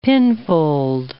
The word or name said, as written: pinfold